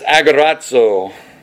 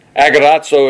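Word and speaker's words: agorazo (ἀγοράζω): The first one, and I'm going to try to pronounce this, is agorazo. Agorazo, it comes from the Greek noun for the marketplace, or the agora.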